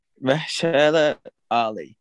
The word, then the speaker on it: Mahershala Ali: Mahershala Ali. – Yes. Sure. Okay. Mahershala Ali.